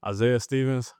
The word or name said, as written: Isaiah Stevens